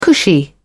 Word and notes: cushy: British English

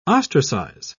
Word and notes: ostracise: British English

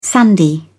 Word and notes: sandy: British English

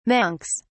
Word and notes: monks: British English

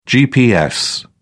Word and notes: gps: British English

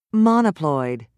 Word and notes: monoploid: British English

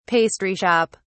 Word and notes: pastry shop: British English

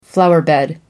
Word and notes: flowerbed: British English

